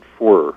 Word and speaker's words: Forer: F as in Frank, O-R-E-R. Richard Foer, F-O-R-E-R. – F-O-R? – R-E-R. – R-E-R, good, because I'm going to get your book.